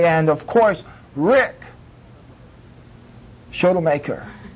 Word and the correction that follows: Schottelmeyer: Is that how you pronounce it? – Schottelmeyer. – Rick. And everybody looks, Rick Schottelmeyer.